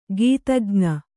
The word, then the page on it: ಗೀತಜ್ಞ: kannada